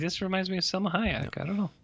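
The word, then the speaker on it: Salma Hayek: Selma Hayek. – Do you like the pronunciation on that? – You really, you killed it.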